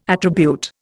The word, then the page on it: attribute: Indian